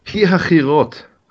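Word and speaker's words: Pi-hahiroth: Now, Yehovah spoke to Moses saying, speak to the children of Israel that they turn and camp before, now how do I pronounce that in Nehemiah? – Pi-ha-chirot. – Thank you.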